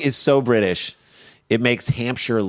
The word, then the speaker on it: Hampshire: According to police in Hampshire, I'm pronouncing that horribly. – Hampshire.